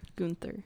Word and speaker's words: Gunther: Gunther.